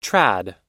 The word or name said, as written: trad